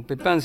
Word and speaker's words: Pepin: Es autora más o menos de 40 composiciones, firmadas como Paula Pepín o Pepin, si lo pronuncian en francés, o con el seudónimo de Pablo del Cerro.